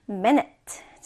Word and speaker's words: minute: 분, 분은 minute. 자, 발음해 볼까요? minute, minute. 자, 뒤에 knit 발음이에요. knit, minute입니다. minute.